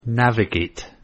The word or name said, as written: navigate